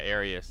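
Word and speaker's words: Arraez: How do I say his name? Arias? – Oh, yeah, Arias.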